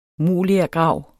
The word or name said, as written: molergrav